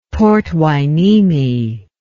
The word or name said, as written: Port Hueneme